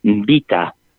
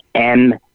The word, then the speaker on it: Mbita: And nobody took me too serious, but eventually we did stage the first World AIDS Marathon it's hard to pronounce it. It's not that I'm stuttering. It's Mbita. That's Mbita. And it was an exciting event.